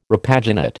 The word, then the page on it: repaginate: American English